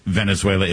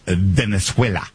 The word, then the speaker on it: Venezuela: It's not Venezuela, it's Venezuela.